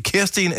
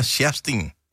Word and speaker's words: Kerstin: Jeg øh, ved, om det udtales ligesom den der reklame, vi har haft i lang tid. Uh, er det uh, Kerstin eller Sjerstin fra Rive? Kerstin. Kerstin? Ja.